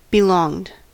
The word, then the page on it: belonged: American English